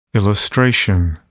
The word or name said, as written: illustration